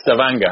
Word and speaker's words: Stavanger: It's uh, Stavanger.